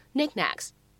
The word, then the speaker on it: knick-knacks: 那么这个单词呢，正确的表达应该是 knickknacks。